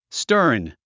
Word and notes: stern: stə’ː r n; スターン

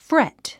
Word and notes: fret: frét; フレェット

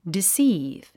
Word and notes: deceive: disíːv; ディスィーブ

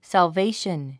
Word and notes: salvation: sælvéiʃən; サルベイション

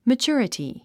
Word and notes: maturity: mətʃú ə rəti; マチュラティ

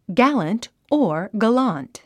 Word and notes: gallant: ɡǽlənt; ギャァラント

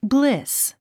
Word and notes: bliss: blís; ブリス

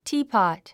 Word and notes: teapot: tíːpɑ̀t; ティーポット

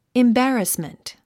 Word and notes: embarrassment: imbǽrəsmənt; インバラスメント

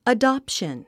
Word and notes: adoption: ədɑ’pʃən; アダプション

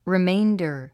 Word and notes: remainder: riméində r; リメインダァ

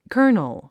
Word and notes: kernel: kə́ː r nl; カーノル

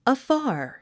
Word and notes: afar: əfάː r; アッファー

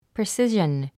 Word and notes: precision: prisíʒən; プリスィジャン

